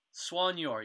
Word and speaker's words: soigneur: So now the title is, I'm the Soigneur. – Soigneur, yeah. Is that how you pronounce it? – It's French, yeah.